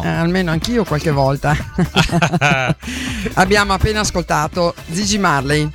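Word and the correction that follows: Ziggy: No, attenzione, no, Gigi. – Zigi. – Zigi. – Zigi. Perché io ho la pronuncia della Z, che è un po' particolare.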